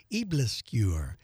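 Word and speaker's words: aebleskiver: It's called Eblisquer. It's spelled A-E-B-L-E-S-K-I-V-E-R, a new take on traditional Danish pancakes.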